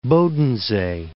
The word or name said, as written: Bodensee